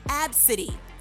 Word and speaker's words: Abcde: spelled A, B, C, D, E. But apparently, it's pronounced Absidy.